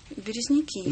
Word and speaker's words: Березники: Березники.